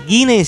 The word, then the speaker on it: Guinney: Guinei se escribe G-U-I-N-N-E-Y.